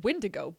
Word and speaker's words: Wendigo: It is a Wendigo. They just pronounced it Windigo, but it is spelled Wendigo. Windigo.